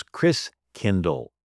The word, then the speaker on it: Christkindl: and the German pronunciation of Christ Child is Christkindl, like kindergarten, kindercare.